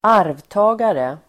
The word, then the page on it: arvtagare: [²'ar:vta:gare]